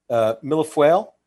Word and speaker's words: millefeuille: This is where I'm going to mispronounce this. Millefeuille. – Yeah, there you go. Nailed it.